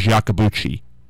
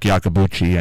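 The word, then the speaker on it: Iacobucci: Nobody ever pronounced his last name right unless you knew him, and he would tell you it was Yakabuchi. But other than that, nobody ever pronounced his last name right, because it began with an I, and usually names that begin with an I do not come out Yakabuchi.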